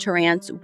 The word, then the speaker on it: Terrence: His name is spelt Terrence, but in a lot of the source material that I found, some people said Terrence, some people said Terrance, and the people who said Terrance were like Tamika's family and friends and people very close to the case.